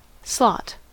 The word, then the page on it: slot: American English